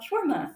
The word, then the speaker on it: shawarma: Shawarma.